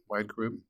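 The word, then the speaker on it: Whide Group: Wide group? – Yeah, that's correct.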